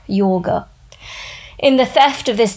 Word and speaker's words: yoga: How many times have you heard the phrase decolonize yoga? The correct pronunciation, by the way, is yoga, or casually pronounced yog.